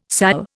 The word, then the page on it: sal: Indian